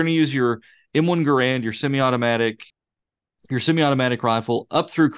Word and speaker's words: Garand: At this point, the warriors are going to come at me and say it's pronounced Garand, but I'm saying what I'm saying.